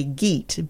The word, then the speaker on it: Geat: And that's spelled G-E-A-T. – Yes. – For our Americanized listeners, we would probably say Geat, but it's really probably Geat.